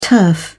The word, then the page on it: turf: British English